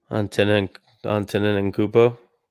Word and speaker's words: Antetokounmpo: And I was hearing a lot about this, about Giannis Antetokounmpo. I can never. Can you pronounce his last name? – Antetokounmpo.